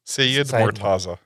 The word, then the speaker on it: Seyedmortaza: Seyedmortaza.